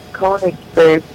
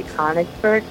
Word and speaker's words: Konigsberg: My whole family says Konigsberg, but I say Konigsberg. So I'm wrong, but I like it better.